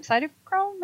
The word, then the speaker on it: cytochrome: So CBD, hemp, like a ton of other herbs, is actually broken down with something called the P450 enzyme, cytochrome, am I saying that right? – Yes.